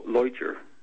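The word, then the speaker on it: Leuchter: Is it Lutcher? Lutcher Report? – Yeah, Fred. I pronounce it as Lutcher.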